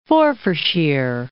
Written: Forfarshire

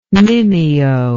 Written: mimeo

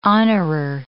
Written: honorer

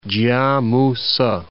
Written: Jiamusi

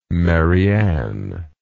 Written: Marianne

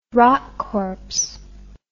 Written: ROTC corps

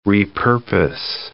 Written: repurpose

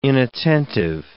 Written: inattentive

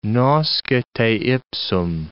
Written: nosce te ipsum

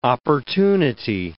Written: opportunity

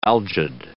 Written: algid

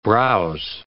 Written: browse

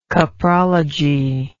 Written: coprological

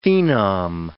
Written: phenom

